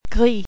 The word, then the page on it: gris: French